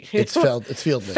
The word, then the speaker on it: Feldman: It's Feldman. – Feldman. There's no I in it.